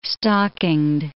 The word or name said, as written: stockinged